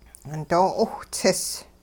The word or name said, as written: doo 'iwhts'is